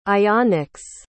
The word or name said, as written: ionics